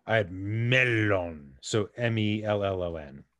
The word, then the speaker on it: mellon: It is indeed spelled melon, M-E-L-L-O-N. but in Sindarin, which is the name of the language that Tolkien comes up with, it is pronounced Melon, which is what Gandalf tries to say.